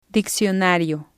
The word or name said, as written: diccionario